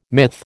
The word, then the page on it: myth: American English